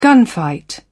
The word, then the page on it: gunfight: British English